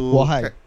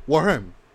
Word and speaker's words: Wareheim: Um, the guy who plays him, Eric Wareheim, I believe that's to pronounce his name. He also Warham. – Warham. Okay, right.